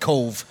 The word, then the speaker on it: Cobh: So I come from a small town in Cork called Cove, which would be phonetically spelled C-O-V-E, but it's actually spelled C-O-B-H.